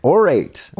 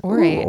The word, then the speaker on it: Aurate: It's weird because it's not spelled like orate. It's A U R A T E.